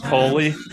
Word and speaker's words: Coley: I heard you say Cooley. – Is it Coley? – John Ford Coley. – Coley. Okay. I apologize for that.